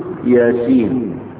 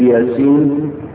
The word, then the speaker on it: ياسين: غلط لانها تنطق ياس ياس تنطق ياس عشان تكتب ياسين الاسم هيبقى ياء ألف سين ياء نون هو ده ياسين.